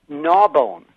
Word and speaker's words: Narbonne: And in fact, I think that's it's actually spelled like that, N-A-R-B-O-N-N-E, but that's not the way people there pronounce it.